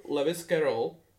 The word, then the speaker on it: Lewis Carroll: Čímž se taky pravděpodobně inspiro, inspiroval Lewis Carroll. Čte se to tak? – Mm-hmm. Autor Alenky v Lewis. Lewis. Prostě jako Leoš, ty vole, koleda.